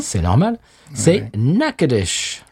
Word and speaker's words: Natchitoches: Alors, Natchitoches, si vous le voyez écrit, c'est pas évident que ça se prononce comme ça. – Non, ça s'écrit Natchitoches. – C'est ça, oui, c'est absolument pas... – Ça se prononce Natchitoches.